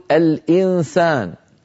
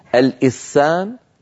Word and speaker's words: الإنسان: طيب هل قلبت النون إلى سين فنطقت سينا مشددة فقلت الإسان الإسان؟ لم أفعل ذلك، إذن هذا العمل ليس بإدغام.